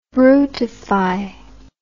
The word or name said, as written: brutification